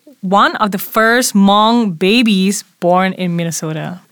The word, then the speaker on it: Hmong: Teacher Garrett told me the H is silent on this word. So it is just Hmong.